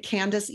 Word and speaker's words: Candyce: Um, you'll find me at Candyce Edelen, spelled with a Y, C A N D Y C E E D E L E N is my LinkedIn profile.